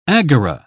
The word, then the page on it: agora: British English